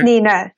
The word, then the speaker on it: Nina: Nina.